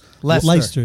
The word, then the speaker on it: Leicester: Is it Leicester, Mass? – Leicester. Yeah.